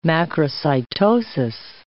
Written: macrocytosis

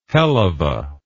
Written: helluva

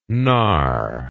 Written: knar

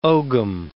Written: ogam

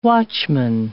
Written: watchman

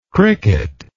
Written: cricketlike

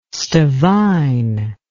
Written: Stevin

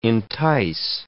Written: entice

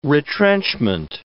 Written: retrenchment

